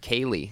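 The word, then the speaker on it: Kayleigh: Kylie McEnany? – Oh, yeah. – How do you say her name? – She was great. Kaylee.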